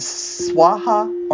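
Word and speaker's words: svāhā: And swaha or soha. So swaha is how they say it in Sanskrit. Soha is how they say it in Tibetan.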